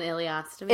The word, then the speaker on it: ileostomy: So how do you say it? – An ileostomy. – An ileostomy. – It's like a colostomy.